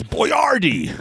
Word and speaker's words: Boyardee: I once got into a fight with another kid in middle school over how to pronounce the guy's name. – It's Boyardee.